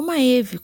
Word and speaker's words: Maeve: Eu estou falando de Maeve, ou Maeve, como alguns preferem pronunciar, a deusa celta da guerra e da caça.